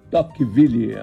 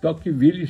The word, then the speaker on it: Tocqueville: Seu nome era Alex de Tocqueville, acho que a pronúncia é essa mesmo.